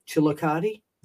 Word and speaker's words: Chillicothe: And I will have copies of this down in Chillicothe.